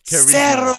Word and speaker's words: carisma: How do you say charisma in Spanish, bro? Charisma. – Yeah, it's like carismo or something like that. – All right. But how about El... No, carisma. Zero carisma.